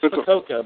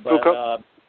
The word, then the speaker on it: Fukuoka: That one I know it's Fukuoka, Fukuoka.